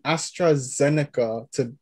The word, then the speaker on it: AstraZeneca: But Dr. Neek, there are different vaccines out here, and you've gotten the vaccine, the AstraZeneca, to be exact. I hope I'm pronouncing that right. – Yes.